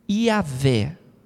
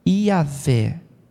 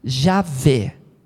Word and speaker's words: Iavé: Esse nome aí, que a gente pronuncia assim: Iavé. Fala comigo. Iavé. Iavé é a pronúncia corretíssima. Mas você pode falar também com o J, que o português usa. Javé. Pode ser também Javé ou Iavé.